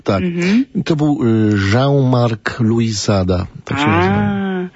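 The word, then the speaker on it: Luisada: Tak, Jean-Marc Louisada. Louisada, mm-hmm. Tak się akcentuje.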